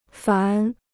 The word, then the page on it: 凡: fán